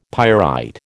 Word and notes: pyrite: American English

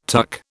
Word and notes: TUC: British English

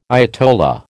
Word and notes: ayatollah: American English

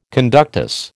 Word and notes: conductus: American English

